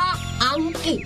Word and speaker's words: อังกฤษ: คำว่าอังกฤษนะเหรอใช่คำว่าอังกฤษต้องออกเสียงควบตัวรอเรือให้ชัดๆสิ